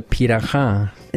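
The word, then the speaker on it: Pirahã: The Piraha.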